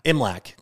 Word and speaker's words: Imlach: Is that how you say his name? Punch Imlach? – Imlach. – God damn it. I'm so close.